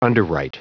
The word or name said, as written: underwrite